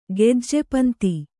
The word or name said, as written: ಗೆಜ್ಜೆಪಂತಿ